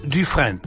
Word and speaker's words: Dufresne: D-U-F-R-E-S-N-E. Etwa ausgesprochen Dufresne. Dufresne. – Ja. – Etwa so, ja? – Ja.